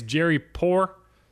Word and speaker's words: Jerry Poor: One note, apparently it's Jerry Poor. Sorry, I've been mispronouncing your name. I was trying to put a little too much French in there, I guess.